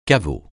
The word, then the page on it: caveau: francais